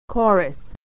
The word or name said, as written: chorus